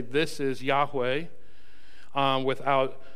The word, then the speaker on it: Yahweh: In our English Bible, it's all caps, standing for the tetragrammaton, or the four consonants, YHWH, or our best guess at the pronunciation would be Yahweh. Some translations might say Jehovah or Lord. Now, Jehovah is the four letters, the consonants with the vowels from Adonai placed over top of them to help not mispronounce or speak the name of God irreverently. And so it would become out Jehovah, and there's no J in the sound.